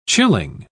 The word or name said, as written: chilling